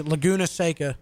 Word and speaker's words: Laguna Seca: Laguna Seca.